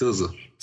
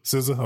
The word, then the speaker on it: SZA: I think her name pronounced SZA. – SZA? Okay, my bad. Just like RZA. That's Well, yeah, like the RZA, like she's the SZA.